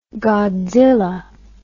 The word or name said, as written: godzilla